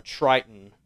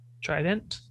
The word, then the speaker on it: Triton: but you notice a triton on the binding of the book. – A what now? – A triton. – Trident? – A triton. – No. – How do I spell it? Sorry. – T-R-I-T-O-N.